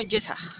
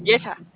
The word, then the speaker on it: Jessa: Al mío, yo me llamo Yesa. – ¿Perdón? – Yesa. – ¿Yesa? – Ajá. – ¿Y-E-S-S-A o Y-E-S-A? – Es J-E-S-S-A. – J-E-S-A, ok.